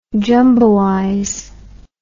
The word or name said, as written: jumboize